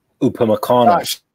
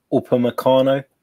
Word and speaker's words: Upamecano: Oh, Upamecano. – What's his name? – Yeah. – Who? – Upamecano.